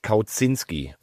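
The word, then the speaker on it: Kauczinski: Die Mama von dem neuen Löwentrainer hat gesagt, der heißt Kauzinski.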